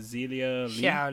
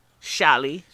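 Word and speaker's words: Xia Li: So afterwards, we got a match between Lacey Evans and how do you pronounce her name? Zelia Lee. – Shali.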